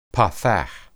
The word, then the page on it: patach: hebrew